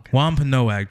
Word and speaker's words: Wampanoag: Did I say that right? The Wampanoag. – Wampanoag tribe. Yeah, the Indian tribe.